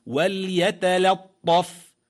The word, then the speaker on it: وليتلطف: أي تنطق بالكلمة وليتلطف وليتلطف بالهجاء لتكون أوضح.